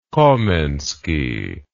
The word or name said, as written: komensky